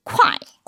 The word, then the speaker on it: 快: 단어는 바로 빠르다 쾌짜입니다. 발음은 콰이.